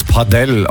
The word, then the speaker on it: Padel: Ich mein, Padel. Es ist wohl Spanisch. Man soll es Padel aussprechen.